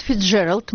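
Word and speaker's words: Fitzgerald: Фрэнсис Скотт Фицджеральд, мы решили произносить это имя так, как его произносят в Америке, родился 24 сентября 1896 года в семье обедневшего бизнесмена.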